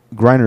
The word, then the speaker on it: Griner: Griner is always spelled the same. It's like grinder, but it's G R I N E R, no D.